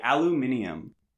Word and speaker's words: aluminium: Or as the British say, aluminium.